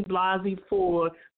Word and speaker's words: Blasey Ford: So, and, and I need to make a correction because um, last Saturday I um, mispronounced the um, the young lady name. Her name is Christine Blasey Ford,